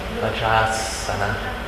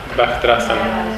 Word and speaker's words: Vajrasana: Vajrasana. Right. – Vajrasana. – Long A. We come a long A, I guess. The Vajra at the end. The A at the end of Vajra.